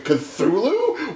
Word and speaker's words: Cthulhu: One day I'm back, you know, I'm back in the back room, and I hear Lynn going, "how do you pronounce this word? C-T-H-U-L-U. I'm like, Cthulhu? What? – Well, to be fair, it's made to be difficult to pronounce.